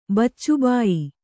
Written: ಬೊಚ್ಚುಬಾಯಿ

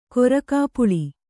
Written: ಕೊರಕಾಪುಳಿ

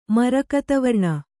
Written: ಮರಕತವರ್ಣ

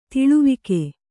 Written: ತಲೆವಾಗು

